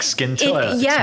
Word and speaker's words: Scintilla: It's a silent C. – Yes. – I was like Scintilla.